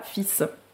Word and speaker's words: fils: J'ai voulu savoir pourquoi on était passé de filius à fils. Donc le L. – C'était plus court. – Le L est purement graphique, donc il s'écrit mais se prononce pas du tout.